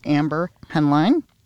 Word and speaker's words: Amber Henline: So again, her name is Amber Henline. I'm pronouncing that correctly, I hope. – Yeah. Yes.